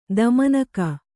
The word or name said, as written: ತಮ್ಮಿನುಗ